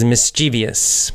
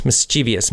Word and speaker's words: mischievous: And in English, a good word for that is mischievous. Mischievous. Mischievous or mischievous. You'll hear it pronounced both ways.